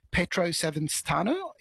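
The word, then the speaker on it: Pietro Savastano: Petro Savastano is how you say his name.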